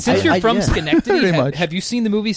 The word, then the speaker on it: Schenectady: It is Schenectady.